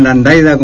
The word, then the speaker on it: Landaglia: Me llamo Carlos Rubén Landaglia, como dijeron, bien pronunciado acá,